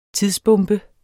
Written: tidsbombe

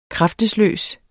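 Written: KRAFTESLØST

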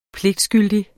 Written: pligtskyldig

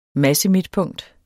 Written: massemidtpunkt

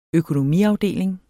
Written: økonomiafdeling

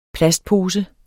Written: plastpose

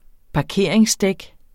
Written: parkeringsdæk

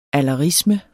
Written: alderisme

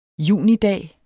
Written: junidag

